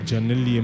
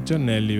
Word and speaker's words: Giannelli: No, però qui, però qui, ho scoperto che non si dice Giannelli, ma si dice Giannelli perché il, il padre l'ha chiamato, l'ha chiamato così in onore di Simone Giannelli, pallavolista italiano.